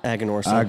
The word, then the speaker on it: Aganorsa: Perhaps you enjoy such ever-popular sticks, such as a 93-rated Guardian of the Farm or Casa Fernandez Aganorosa. – Aganorsa. – Aganorsa. Yep, I messed that one up too.